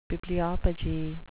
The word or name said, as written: bibliopegy